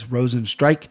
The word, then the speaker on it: Rozenstruik: I heard Anik pronounce it right. It's Rozenstruik. I love that he took this fight.